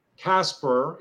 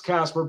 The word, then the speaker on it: Kaspar: It's pronounced Kaspar. It's pronounced Kaspar, but it's spelled Kaspar.